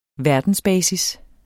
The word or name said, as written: verdensbasis